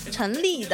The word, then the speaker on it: 陈粒: 该曲目呢发行于二零一五年的二月二日，收录在陈露陈粒的首张个人专辑《如也》当中。你为什么要笑我？ – 竟然读成了陈露，笑死我了！